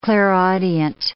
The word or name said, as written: clairaudient